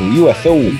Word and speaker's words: UFO: Falei certo? UFO? – Pô, a galera aqui, pelas nossas quebradas aqui, fala UFO mesmo.